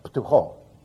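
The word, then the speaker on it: ptucho (πτωχός): a ptucho. Now, don't try to pronounce that. You try that when you get home. It's got a P-T and a C-H in there, all together, ptucho.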